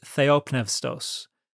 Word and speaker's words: theopneustos: So the word in Greek there is literally, and for those who are not aware, I use modern Greek pronunciation when I pronounce the Greek of the New Testament, so it's going to sound a little different to you. But the word there in Greek is theopneustos.